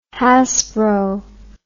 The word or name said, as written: hasbro